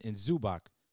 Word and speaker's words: Zubac: And they also lost their one big guy in Zubac. That's how you pronounce his name.